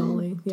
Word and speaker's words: Tolle: Is that how you say his name? – Tolle, yeah. – Tolle.